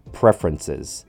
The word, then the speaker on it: preferences: Lastly, we have preferences. Four syllables. I know this has four syllables, but it actually sounds like three instead.